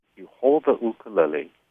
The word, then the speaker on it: ukulele: Now, according to Roy Sakuma, who's been teaching the instrument for more than 50 years, so he should know, it's pronounced ukulele.